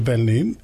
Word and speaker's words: pernil: You know, it does begin with the pernil.